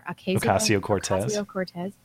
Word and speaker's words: Ocasio-Cortez: Ocasio-Cortez.